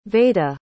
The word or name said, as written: vayda